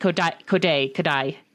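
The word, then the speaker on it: Kodaly: I think it's like Kodai. Is that right? Kodai. It's like spelled Kodali, but it's pronounced Kodai.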